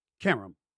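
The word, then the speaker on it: Qerim: She says, Stephen, Karam rhymes with harem. Karam, harem, Karam, harem. Okay, I got it.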